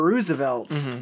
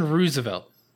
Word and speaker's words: Roosevelt: Why are we saying Roosevelt? It's spelled that way. – That's how I say it. – Right. It's spelled that way. – But it's probably said Roosevelt, right? – You guys say Nike or Nike?